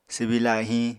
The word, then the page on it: swbi lahiñ: apatani